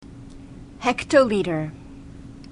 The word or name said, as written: hectoliter